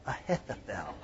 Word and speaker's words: Ahithophel: But for what really got David was that one of his own close counselors, a fellow named Ahithophel, there's a mouthful, Ahithophel. Would you like to pronounce that with me? Let's say it together, Ahithophel. You could almost spit on somebody.